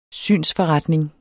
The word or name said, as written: synsforretning